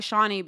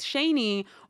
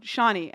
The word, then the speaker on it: Shani: I figured it was him because, I mean, my name is actually Shani, but I go by Shawnee because growing up, people would always mispronounce it as Shani or just like names I didn't like. So I was like, no, Shawnee.